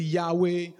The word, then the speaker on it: Yahweh: It's pronounced Yud Hey Wah Hey, but basically Yahweh.